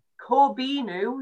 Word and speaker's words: Corbeanu: Um, and then obviously Corbeanu, which is apparently how you pronounce it.